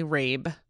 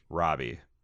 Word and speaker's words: Rabe: Remember, Rabe rhymes with Gabe. I read this email before I started the podcast, and that's the only reason I remembered. – It's constant because you see Rabe and you want to say Robbie.